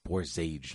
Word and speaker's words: Borzage: Frank Borzug became the first person to win a second Best Director Academy Award. – Borzage, not Borzug. Get his name right.